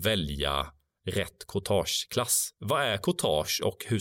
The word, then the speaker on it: courtage: Courtage. Jag vet inte hur man säger det. – Men, nej, det var ett perfekt uttal.